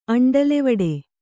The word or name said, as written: ಅಂಡಲೆವಡೆ